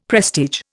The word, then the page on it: prestige: Indian